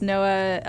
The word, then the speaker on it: NOAA: Yeah, and we have to thank, I believe it's NOAA, I want to say Office of Exploration and Research. – That's right. – Did I say it right? Yeah, that supported this.